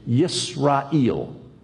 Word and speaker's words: Yisrael: Well, in the middle of the second millennium BC, around 1500 BC, The way you would have said Israel is to say Yisrael.